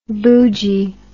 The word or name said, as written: boojie